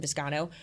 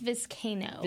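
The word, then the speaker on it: Vizcaino: Before I get back to that, I did want to mention, you talked about the kicking situation with Tristan Viscano. Have we decided how to say his last name? – I think it's Viscano. – Viscano.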